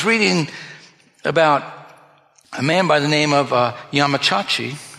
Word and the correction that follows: Yamaguchi: Yamachachi or Yamaguchi. Yamaguchi.